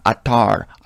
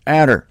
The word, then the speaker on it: adder: It's not attar. I wish it was, because that's more exotic, but it's adder.